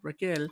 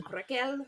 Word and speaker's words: Raquel: So that's how I knew how to say it. Raquel. – Raquel.